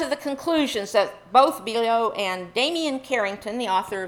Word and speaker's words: Biello: I'm going to butcher his last name, Biello, B-I-E-L-L-O, Biello.